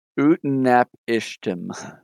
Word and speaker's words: Utnapishtim: I will spell it out after. Utnap Ishtim. So UTNAPISHTIM.